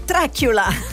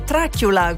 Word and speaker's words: Dracula: Leggi Dracula, anzi pronuncia Dracula. Come dice il mio amico Max Giannini in Good Morning Kiss Kiss, è Dracula come Draculella, che è una specialità culinaria tutta partenopea, veramente squisita.